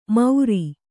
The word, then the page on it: ಮೌರಿ: kannada